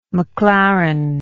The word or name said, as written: McLaren